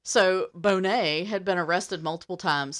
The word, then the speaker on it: Boney: One of the samples belonged to a former felon, Charles Bonney, which is spelled Boney, B-O-N-E-Y, which I think is funny, but it's pronounced Bonney, but I kind of want to just call him Boney because...